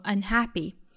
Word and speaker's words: unhappy: 本身 unhappy 这个词的重音呢应该是落在 ha 这个音节上的我们去查字典可以看到这个词应该读成 unhappy